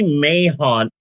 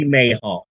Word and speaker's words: Mahon: One of the leading directors of Nudie Cuties was a guy named Barry Mahon, and I'm almost positive that the H in his last name is silent, but I believe that all letters matter.